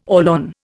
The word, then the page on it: Orlon: Indian